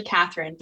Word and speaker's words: Catherine: I'm actually Catherine, but spelled with a C, not a K.